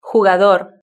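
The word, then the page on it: jugador: Spanish (Spain)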